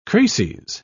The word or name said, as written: crases